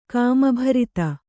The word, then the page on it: ಕಾಮಭರಿತ: kāmabharita